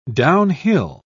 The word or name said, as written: downhill